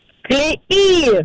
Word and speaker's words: клеи: В данном слове во множественном числе ударение всегда падает на последний слог. Поэтому правильно говорить еще раз. Как? – Клеи!